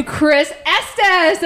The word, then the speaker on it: Chris Estes: So without further ado, please give a warm welcome to Chris Estes.